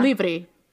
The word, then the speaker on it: Livre: Livre. Livre. Yeah. Livre. With a V, yeah. Like Victor.